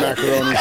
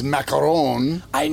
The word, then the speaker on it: Macaroni: You clearly can't trust anything Sam Macaroni said. – Yeah, of course. – By the way, his proper pronunciation is Macaron. – I know. – And he goes by Macaroni.